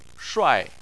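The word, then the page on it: shuai4: tone 4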